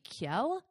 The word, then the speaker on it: Kjell: So his name is spelled K-J-E-L-L, which I thought it'd be Kjell. But then I looked it up and it said Kjell.